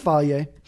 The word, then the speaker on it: Fage: But, and Faye is how to pronounce phage. Or Faje, or whatever I was trying to pronounce last time or a few times ago. It's Faje.